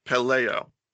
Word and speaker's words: Pelayo: It's P-E-L-O, oh, I'm sorry, P-E-L-A-Y-O, Cynthia Peleo.